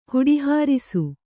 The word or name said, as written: ಹುಡಿಹಾರಿಸು